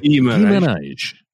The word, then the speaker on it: Guimarães: Guimarães. I'm encouraging your... – Yeah, it's you. It's you because you pronounce it properly. – Guimarães.